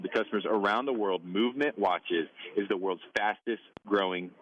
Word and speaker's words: MVMT: Movement watches, when you see it in ads or on Instagram or whatever, is going to be spelled MVMT, but it's pronounced movement, was founded on the belief that style shouldn't break the bank.